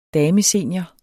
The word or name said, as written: damesenior